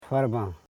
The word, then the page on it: farban: far.ban